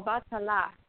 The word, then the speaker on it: Obatala: Do you work with, I might be saying it wrong, Obatala? – Oh, Obatala.